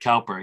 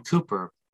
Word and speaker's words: Cowper: William Cooper, sometimes wrongly said Cowper. He actually specified how his name was to be pronounced. But William Cooper, C-O-W-P-E-R, who wrestled with mental illness mm-hmm.